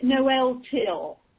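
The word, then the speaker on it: Noel Tyl: Is it Noel Till or Noel Till? – He pronounces it Noel Till.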